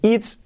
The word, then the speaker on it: it's: Primero ese it's, no es it's, la I como una E, letra clave. It's, it's, it's. Importante que la T la marquemos, que no digamos es, no.